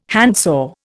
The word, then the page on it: handsaw: Indian